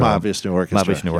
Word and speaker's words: Mahavishnu: Mahavishnu Orchestra.